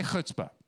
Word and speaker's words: chutzpah: Say chutzpah. There you go. If you say it right, the Yiddish way, the person on the front, sitting in front of you can feel it on the back of their neck.